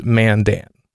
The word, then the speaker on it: Mandan: And yes, it's pronounced Mandan.